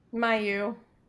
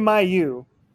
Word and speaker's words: Mailloux: Mailloux. – Thank you. Logan Mailloux.